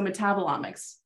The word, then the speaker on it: metabolomics: metabolomics. Do you want to type in? – Yes. – How do I say it correctly? – It's like metabolism with omics. Yes. So metabolomics.